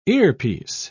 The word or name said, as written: earpiece